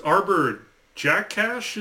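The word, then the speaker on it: Arber Xhekaj: It's Arbor Jackash is what I looked online to see it's pronounced as.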